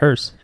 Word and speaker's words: hearse: So also I got to see the morgue that they kept the bodies in until the Hearst. You're talking about the car? – Yeah, how do you say it? – Hearse. – Okay, that one.